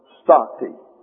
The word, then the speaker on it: stacte: The proper ingredients include, as you may remember, number one, stati. Now that's the English word, S-T-A-C-T-E. Stati is the way it's pronounced in the English. The Hebrew is N-A-T-A-F, Nataf.